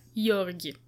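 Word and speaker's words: György: If you anglicize it, it's George. So, you know. – Let's not anglicize it, though. Like That's an interesting stand. But yeah, Jörgi.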